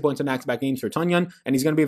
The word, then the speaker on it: Tonyan: A couple people tell me now that it's pronounced Tonyan, Tonyan. It's been pronounced for 2 years.